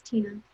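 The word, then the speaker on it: Tenna: It's Tina Torres. My name is spelled a little strange. It's T-E-N-N-A, and my last name is Torres, T-O-R-R-E-S. So it's like Tena, it's Tina.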